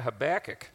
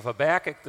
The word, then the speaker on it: Habakkuk: We've been talking about the different ways you can pronounce the book Habakkuk, and they pronounce it in some parts of America, Habakkuk.